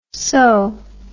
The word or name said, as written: soh